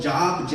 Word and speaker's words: Job: Uh, it's, it's, spelled Job, J-O-B, but it's pronounced Job.